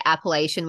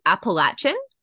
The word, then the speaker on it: Appalachian: I usually would say Appalachian, which is wrong. So it's Appalachian. – Yeah. Well, it depends on who you ask. So like, I mean, you would say Appalachian.